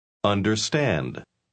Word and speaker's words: understand: Understand. – Understand. ¿Oyó usted el sonido de la R norteamericana en la palabra understand? La R norteamericana nunca se pronuncia como la R en español.